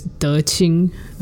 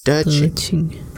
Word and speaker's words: De Qing: Her Dharma name is De Qing. So it's D-E space Q-I-N-G. So Q is always pronounced like C-H. – Mm-hmm. De Qing.